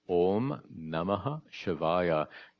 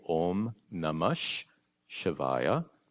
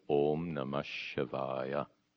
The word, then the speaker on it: Om Namah Shivaya: One quick note on pronunciation. Om Namaha Shivaya. That Namaha, that Vasarga of Namaha, combined with the Sha of Shiva, becomes a double Sha. In pronunciation, it's actually Om Namash Shivaya, and the two Shakaras merge. So the proper pronunciation then is Om Namah Shivaya.